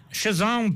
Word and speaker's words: Cézanne: Cézanne, Paul Cézanne, très bien.